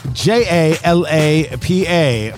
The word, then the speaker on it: Jalapa: Jalapa, J A L A P A, pronounced Jalapa. It's a region in Nicaragua.